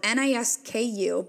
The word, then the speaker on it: Nisku: It's spelled N I S K U, but I believe the pronunciation is Nisku, Alberta, which is a small town within Leduc County.